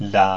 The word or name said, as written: la